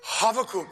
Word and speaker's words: Habakkuk: His name in Hebrew would be pronounced differently than Habakkuk, our English pronunciation. The English spelling of the Hebrew would have the CH instead of the H because it's kind of got that huh sound. And so it'd be Habakkuk would be his Hebrew pronunciation, Habakkuk. And